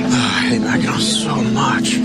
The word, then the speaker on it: Mackinac: It's spelled, why do they spell it Mackinac when it's Mackinac? It doesn't make any sense.